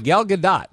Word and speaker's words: Gal Gadot: By the way, Gal Gadot. That's the pronunciation.